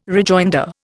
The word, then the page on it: rejoinder: Indian